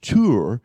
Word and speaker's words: Tyre: And it's formed from the Hebrew word, it really should be pronounced Tur, because it is formed from the Aramaic Hebrew word Sur, which means the solid rock.